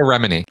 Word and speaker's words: Remini: What is it again? – Leah Remini. – Leah Remini. You put it on the first syllable, Remini. How realistic is Leah Remini's documentary? – It's to the comma accurate.